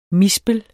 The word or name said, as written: mispel